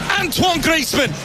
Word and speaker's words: Antoine Griezmann: That is number 300 across international club games for that man, Antoine Griezmann. – Antoine Griezmann, lo pronuncian bien, la verdad. Obviamente a Antoine Griezmann no les cuesta mucho decirlo.